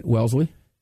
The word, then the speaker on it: Wellesley: Wellesley.